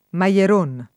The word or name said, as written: Maieron